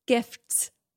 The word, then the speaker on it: gifts: You really don't pronounce the T, eh? – Gift. – Gifts. – Gifts. It hurts. It hurts to put those three consonants together, gifts. The F, T, and S.